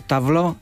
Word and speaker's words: tableau: En francés, por ejemplo, sí, cuando decimos tableau, termina en E-A-U y se pronuncia O.